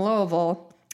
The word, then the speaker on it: Louisville: Not from Whitesburg, from Louisville.